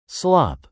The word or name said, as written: slop